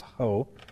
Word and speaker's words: ho: Well, first the society notes that in the Greek text there is a definite article, the, which is the Greek word, it's pronounced ho, before the first occurrence of God.